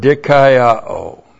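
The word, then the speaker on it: dikaiō (δικαιόω): The adverb noun means now, and then we, our good friend in Romans, the verb dikaiō. D-I-K-A-I-O long O. Dikaiō.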